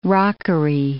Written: rockery

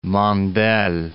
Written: Mandel

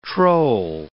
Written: troll

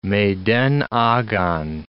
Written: meden agan

